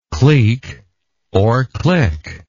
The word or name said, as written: cliquy